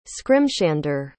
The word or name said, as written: scrimshander